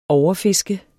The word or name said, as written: overfiske